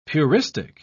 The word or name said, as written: puristic